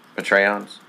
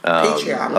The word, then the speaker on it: Patreon: Patreons. You know, Patreon.